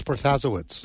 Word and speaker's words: Protasiewicz: I think it's Protasiewicz.